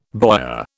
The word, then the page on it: boyar: British English